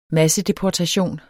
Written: massedeportation

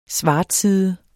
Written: svartside

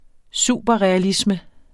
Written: superrealisme

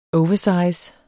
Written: oversize

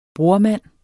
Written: brormand

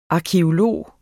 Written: arkæolog

